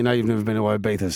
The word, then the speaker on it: Ibiza: I think the fact that Wave the Bill, number 3, has joined inside its main rival, Mr. Ibiza, number 4, is that how you pronounce it? Ibiza? Not yeah. Ibiza? – No, yeah. It's Ibiza.